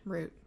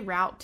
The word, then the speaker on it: route: How do you pronounce the word? How do you say this word? – Root. I say route too, but I only say route when I'm ordering at Sonic.